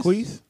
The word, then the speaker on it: Jacquees: Is it Jacquees? – Jacquees.